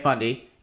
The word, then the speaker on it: Fundy: The Bay of Fundy.